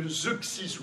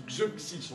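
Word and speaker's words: Zeuxis: Vous vous rappelez de Xuxis, ou Xuxis, on peut le prononcer de plusieurs manières.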